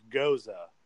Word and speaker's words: Gose: Goes is spelled G-O-S-E, but it's pronounced like goes, like G-O-E-S. Like, I'm, you know, we good. Well, no, I can't think of that in a sentence. Mm. Yeah. Anyways, it's spelled like G-O-E-S, but with a U-H at the end, so it's Goza.